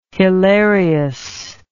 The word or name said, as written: hilarius